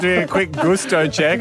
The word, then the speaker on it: gusto: Also, regarding gusto, I assume it's the Spanish word for taste. And in that case, it is definitely pronounced gusto. No.